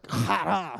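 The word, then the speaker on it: chara (χαρά): And you almost have to kind of like chara, you know, like to say it right. Like if you have to spit after you say that word, you've pronounced it pretty well.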